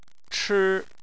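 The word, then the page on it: chi1: tone 1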